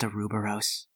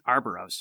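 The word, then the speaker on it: Ouroboros: It's pronounced Aruboros. – I thought it was pronounced Arboros. – No, that is extremely wrong. – Arboros. – You're dropping an O.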